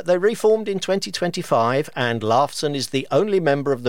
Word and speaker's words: Lifeson: It's spelt Lifeson, but pronounced Lifeson.